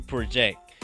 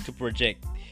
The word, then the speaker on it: project: Et avant d'aller, bonus ma bano, c'est que nous avons stress là sous non, il est tombé sous première syllabe là, pour première syllabe là. Alors que sous un verbe là, il est tombé sous deuxième syllabe là. Project, nous avons project, nous avons pra plus long, nous dit project. Et deuxième là, nous dit project. Nous avons que jack plus long.